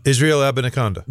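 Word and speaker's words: Israel Abanikanda: Can you say his name? – Oh, yeah. It's Abaconda. Yeah. – Israel Abaniconda.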